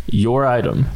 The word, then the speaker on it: Yiadom: Here's the pronunciation guide from Boston College. Isaac Yiadom. – Your item. – Like you're leaving. No, like you're leaving the supermarket and like, can't you forgot your item?